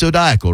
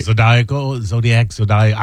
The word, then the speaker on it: zodiacal: It might draw in the people who might be interested in zodiacal radio programs. – Yeah. Yeah. – Not Zydeco radio programs, but zodiacal. No, it's zodiacal, right? I've got that right. – Zodiacal. I would say zodiacal.